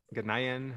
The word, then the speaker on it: Ghanaian: this looks like that those Ghanaian, how do you say that, people from Ghana? – Ghanaian.